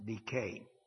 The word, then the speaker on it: dike: D-I-K-E, pronounced decay.